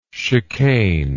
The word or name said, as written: chicaner